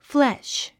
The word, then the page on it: flesh: fléʃ; フレシュ